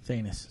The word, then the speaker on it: Thanos: So when Thanos is defeated in the movies, it's going to be off Thanos. – Thanos, sorry.